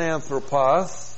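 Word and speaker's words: anthropos (ἄνθρωπος): Man is the noun anthropos. Looks like this in the Greek. A-N-T-H-R, long O-P-O-S, anthropos.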